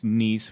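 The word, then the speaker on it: Kneis: It's my last name, which is Niece, which is spelled weird. It's K-N-E-I-S, and then it's Niesage, so K-N-E-I-S-A-G-E.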